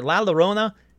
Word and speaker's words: La Llorona: La Llorona. Look, I said it right that time. Sorry I didn't say it right on the episode.